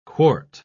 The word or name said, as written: quart